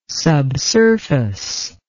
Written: subsurface

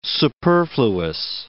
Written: superfluous

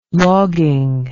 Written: logging

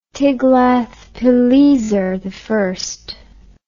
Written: Tiglath-pileser I

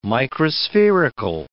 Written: microspherical

